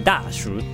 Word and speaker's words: 大暑: この中国語読みが、ダ暑シュっていう読み方になるんですよ。覚えてください。ダーシュっていう、ダーシュっていう呼び方なんですね。